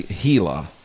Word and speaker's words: Gila: But out here we say Gila. Because it's Spanish. – Oh, yeah, Gila River, okay. – Yeah, Gila River. – Yeah, there's a soft G sound.